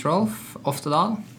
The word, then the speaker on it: Rolf Oftedal: Yeah, my name is Rolf Oftedal.